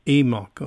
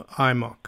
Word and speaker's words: AMOC: So tell us about the Atlantic Meridional Overturning Circulation, AMOC, apparently pronounced EMOC or IMOC.